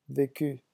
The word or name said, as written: vecu